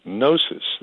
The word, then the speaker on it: gnosis: Now, Gnosticism is a Greek philosophy, and the word comes from the Greek word gnosis. That's G-N-O-S-I-S. It's a silent G, and it means to know.